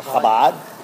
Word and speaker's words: Chabad: Chabad. The Chabad. That's the way you pronounce it, right? – Correct.